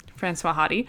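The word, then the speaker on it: Françoise Hardy: but Francois Hardy. Oh, no, that's right. Francois Hardy, who's a very famous kind of pop singer in that era of time in France, and her songs are beautiful.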